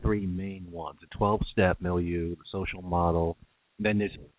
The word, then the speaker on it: milieu: It's really the term is actually called MILIEU, and it's spelled M-I-L-I-E-U.